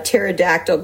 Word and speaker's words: pterodactyl: Why can't you hear a pterodactyl going to the bathroom? Because the P is silent.